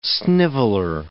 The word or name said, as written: sniveler